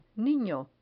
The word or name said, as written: nino